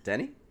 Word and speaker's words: Denis: Is it Denny? – Denny. Villeneuve? – So you pronounce it in French.